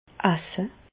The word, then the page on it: esse: Bas-Rhin; Reichshoffen